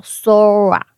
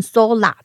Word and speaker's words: Sora: 那么，这就是全世界最受关注的人工智能创业公司 OpenAI，它发布了首个文字生成视频的大模型，叫 Sora，应该呢念成 Sola 才对。为什么？因为它是从日本字这个“ 空” 这个字来转变出来。